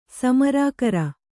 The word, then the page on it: ಸಮರಾಕರ: Kannada